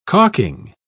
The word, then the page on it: calking: British English